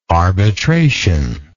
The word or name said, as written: arbitrationist